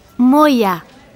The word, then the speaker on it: Moya: G-A-B-R-I-E-L-L-A. – G-A-B-R-I-E-L-L-A. Thanks. And what's your surname? – Moya. That's M-O-Y-A.